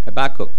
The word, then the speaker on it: Habakkuk: I've been having trouble pronouncing the word Habakkuk. You know, is it a Habakkuk or Habakkuk? Habakkuk. One time I just tell my sons when they're growing up, I said, just have a cook.